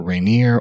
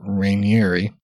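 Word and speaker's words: Raniere: I've heard his last name pronounced either Rainier or Rainieri.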